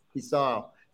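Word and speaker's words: Fèis Ìle: How do you pronounce it? Is it the Fishar or Fissile.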